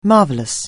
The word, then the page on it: marvellous: anglais